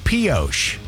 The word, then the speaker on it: Pioche: And the town's called Pioche. P-I-O-C-H-E. Am I saying it right? – You are.